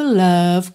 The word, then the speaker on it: love: E o love, claro, sempre lembrando uma palavra que a gente repete tanto aqui, em letra de música, o E final é mudo, você termina o som na letra V, love, e o O não é um O mesmo, né? Love, e sim love, love. Pensa naquele som bobo, ok?